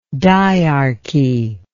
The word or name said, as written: dyarchical